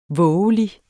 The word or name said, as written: vovelig